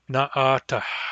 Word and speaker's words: naatah: People with English accents will tend to drop that back H at the end, but it should not be dropped. Naatah.